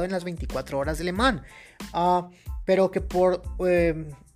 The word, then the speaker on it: Le Mans: Tengo entendido que la S al final no se pronuncia, así que es Le Mans.